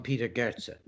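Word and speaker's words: Peter Gøtzsche: These are the words of Peter Gotcha and Peter Getch. How do you say it? – Yes, on this show we call him Peter Goetze.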